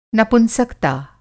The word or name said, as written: नपुंसकता